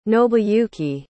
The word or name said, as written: nobuyuki